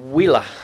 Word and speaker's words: Huila: Huila? – Huila.